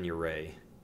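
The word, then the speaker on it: Jarre: I'm gonna say his name is Kevin, Kevin Uray. Kevin. It's J A R R E, and his dad was his stepdad or something was French, and, and so the name is French.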